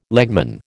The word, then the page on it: legman: American English